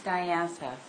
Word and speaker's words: Diantha: It's pronounced Diantha. – Okay. – Like the coronation family.